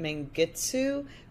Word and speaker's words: Mengistu: Usually people confuse like the ending, like they pronounce it Mengistu rather than Mengistu.